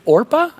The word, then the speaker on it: Orpah: Her birth name is actually Orpah on her birth certificate. And people mispronounce it so often, they started calling her Oprah and she just kind of went with it over time.